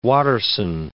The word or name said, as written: Watterson